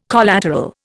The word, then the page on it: collateral: Indian